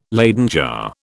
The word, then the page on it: Leyden jar: British English